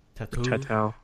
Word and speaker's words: Tautou: Tautou.